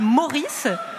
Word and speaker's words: Maurice: Alors, jeudi, sur l'île Meurice... – Non, ouais. Non, vous prononcez mal. C'est l'île Maurice...